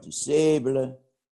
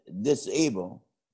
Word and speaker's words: DuSable: And because they were so politically organized, they changed the school name from New Winterfellows to Jean-Baptiste Point du Sable. But pretty soon, jokesters were calling it Disable. – Oh. – And he changed the pronunciation to Dusable.